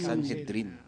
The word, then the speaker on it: Sanhedrin: Sanhedrin. Yan, tama yan. – Sanhedrin. – Oh, ang galing. Kanina tinatanong ko, paano i-pronounce yun eh? Sanhedrin ba? Paano pa spell nga yung... – Di ba Sanhedrin? – Tagalog. Tinagalog ko lang yung Sanhedrin. – S-A-N. – Sanhedrin. – S-A-N-H-E-D-R-I-N. – Center H na tayo ngayon. Sanhedrin. – Paki-spell nga ulit. S-A-N-H-E-D-R-I-N.